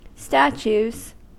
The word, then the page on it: statues: American English